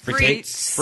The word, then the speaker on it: frites: We also had the frites. Am I saying that right? Frites? – Frites.